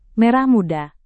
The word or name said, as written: merah muda